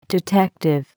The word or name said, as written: detective